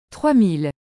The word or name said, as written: 3000